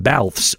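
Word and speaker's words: Balthvs: But first, let's check out your song of the day, which today comes from a group called Balfs, although it would be spelled in a way that maybe you would have trouble with knowing that that's how it's pronounced. So it's B-A-L-T-H-V-S, kind of like the band Churches does.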